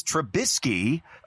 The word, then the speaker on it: Trubisky: It's Trubisky.